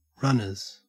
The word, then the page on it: runners: Australian English